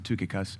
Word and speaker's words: Tychicus: Ephesians 6:22, Paul said, and that he, Tychicus, or Tychicus, it's Greek pronounced Tychicus, Tychicus might comfort your hearts.